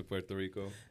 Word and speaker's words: Puerto Rico: You think America would ever give any respect to Puerto Rico? – I mean, it's not happening. – You just gave respect by pronouncing it right.